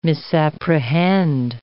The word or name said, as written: misapprehend